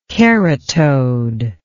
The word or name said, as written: keratode